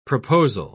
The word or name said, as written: proposal